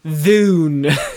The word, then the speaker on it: Dune: And so, we are talking Dune, as they pronounce it. – Dune? – Dune. It's Dune? June. – excuse me, I said June. – June. – With a J.